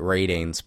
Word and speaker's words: RTINGS: I like a website that, I guess you pronounce it Ratings, but it's just spelled R-T-I-N-G-S.